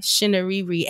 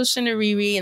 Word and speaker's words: Chinouriri: So, Chinouriri. Shinariri. And I looked her up on YouTube, found an interview because I wanted to make sure I said it right. So, Rachel Shinariri.